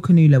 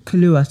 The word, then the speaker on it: Kaluuya: I don't think Daniel Kaluuya went. – Kaluuya, sorry. I keep saying his name wrong.